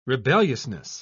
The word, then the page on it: rebelliousness: British English